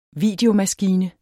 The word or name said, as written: videomaskine